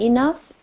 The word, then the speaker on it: enough: Like in enough, E-N-O-U-G-H, G is silent.